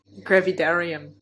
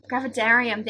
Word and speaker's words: Gravidarum: Gravidarum, if that's how you say it? – Gravidarum. – Gravidarum, there we go.